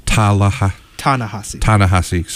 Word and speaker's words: Ta-Nehisi: And this debate between Cornel West and Ta-Nehisi Coates. Yeah. Am I saying his name incorrectly? – Ta-Nehisi, sorry.